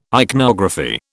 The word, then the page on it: ichnography: British English